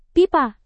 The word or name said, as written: pipa